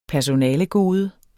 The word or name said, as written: personalegode